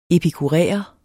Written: epikuræer

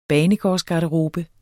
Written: banegårdsgarderobe

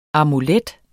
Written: amulet

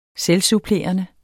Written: selvsupplerende

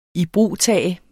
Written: IBRUGTAGE

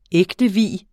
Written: ægtevie

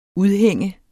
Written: udhæng

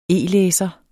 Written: e-læser

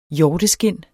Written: hjorteskind

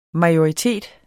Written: MAJORITETS

